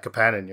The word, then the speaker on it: Kapanen: The two picks in the second round, Riley Kidney and Oliver Kapanen. Kapanen. You're right. That should be pronounced the proper Finnish way.